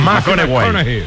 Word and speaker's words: McConaughey: Mateo McConaughey. – Sí, sí, sí, sí, sí. – Que hay que ir corrigiendo la pronunciación. – McConaughey.